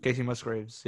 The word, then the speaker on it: Musgraves: I think the closest I've come to liking the country from today would be, is that Kacey Musgraves? That's how you say her name? – Kacey Musgraves, yeah.